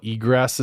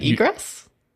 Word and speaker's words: egress: Is it really egress? – I think I said it right. It sounds like a bird, doesn't it? I think that's an egret, though. – Yeah, that's a bird, but I believe you. I just haven't heard it myself.